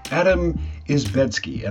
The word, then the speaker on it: Adam Izdebski: You are listening to the Padverb Podcast, and I am joined by Adam Izdebski.